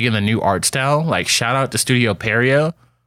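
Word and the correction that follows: Pierrot: or Periot. However you pronounce it. – Piero. – Studio Piero.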